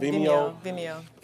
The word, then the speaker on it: Vimeo: Am I pronouncing that right? – Vimeo.